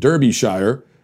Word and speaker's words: Derbyshire: To add the correct sinister touch and not distract us with the comical pronunciation Derbyshire, please note that the name of this county is pronounced Derbyshire, with the emphasis on the first syllable.